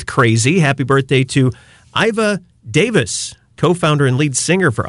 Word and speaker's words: Davies: I say Davis, it's spelled Davies, but he's Australian, so maybe like the British, like Ray Davis of the Kinks. It's spelled like Davies, but pronounced Davis.